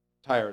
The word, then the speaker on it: Tyre: So the word of the Lord came again unto me, saying, Son of man, take unto the prince of Tyre, or Tyrus, Tyre is how you pronounce it.